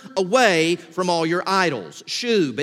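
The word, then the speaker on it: shub (שוב): The one that is here is the Hebrew word pronounced shub. It rhymes with the word tube, but it's a shub.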